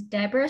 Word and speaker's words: Deborah: My name is Deborah Sloane Art across all mediums.